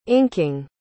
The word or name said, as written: inking